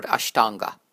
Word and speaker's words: ashtanga: Take the word ashtanga. In this one, the second A is long and the first is short, ashtanga.